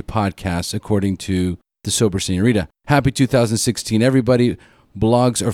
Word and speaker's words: Señorita: Actually, it's pronounced Sober Señorita. I'll bring out my Latino flavor.